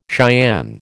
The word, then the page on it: Cheyenne: American English